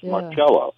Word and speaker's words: Marcello: Carlos Marcello, or Marcello, correct pronunciation is Marcello, he was right there in Metairie.